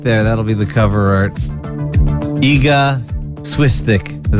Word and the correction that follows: Iga Świątek: Is that how you say it? – No, in Polish you say Iga Świątek, but that's.